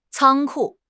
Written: 仓库

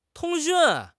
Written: 通讯

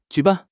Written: ju3 ban4

